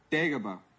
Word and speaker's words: Dagobah: I didn't say it right. wait, how's it pronounced? – Dagobah.